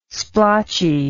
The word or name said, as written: splotchy